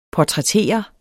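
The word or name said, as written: PORTRÆTTERES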